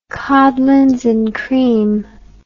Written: codlins and cream

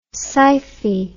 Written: scyphi

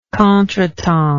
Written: contretemps

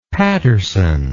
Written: patterson

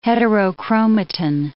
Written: heterochromatin

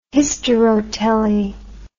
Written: hysterotely

